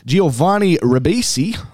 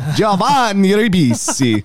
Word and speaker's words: Giovanni Ribisi: Giovanni Ribisi. Oh, God, I've butchered that. Giovanni Ribisi as notorious mobster Joe Colombo.